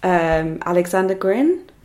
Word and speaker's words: Alexander Grin: Very nondescript title, could mean anything, but it's based on a short story, or a story by the Russian author Alexander Grin. Is that how you pronounce it? Yeah, okay.